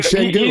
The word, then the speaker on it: Şengün: Şengün, how do you say his name? – Yeah, Alperen Şengün.